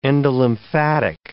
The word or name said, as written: endolymphatic